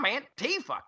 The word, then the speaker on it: Antifa: I don't want to be on a beach beating up some anti-fa. They need a better name. Can we give them a nickname? – We should have a contest. – It's actually pronounced Antifa.